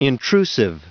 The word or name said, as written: intrusive